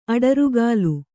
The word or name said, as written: ಅಡರುಗಾಲು